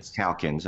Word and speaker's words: Calkins: Calkins. – I was going to pronounce that with the long A.